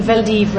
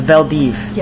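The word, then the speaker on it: Vel d'Hiv: The Veldiv Roundup. – The Veldiv.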